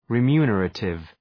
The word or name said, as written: remunerative